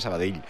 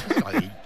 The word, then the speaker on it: Sabadell: El cachondo de Kiki Lisa dice, pronuncias muy bien Sabadell. Dice, mis respetos. – Sabadell. A mí me lo decían mucho cuando estaba en Primera Federación, porque yo también decía Sabadell, que es como se dice.